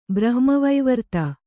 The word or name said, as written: ಬ್ರಹ್ಮವೈವರ್ತ